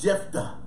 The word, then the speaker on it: Jephthah: Yes, Jephthah.